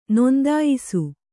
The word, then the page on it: ನೋಂದಾಯಿಸು: Kannada